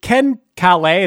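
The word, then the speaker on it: Ken Caillat: Did you, Ken Calais, is that how you pronounce his name? – I believe so, yeah.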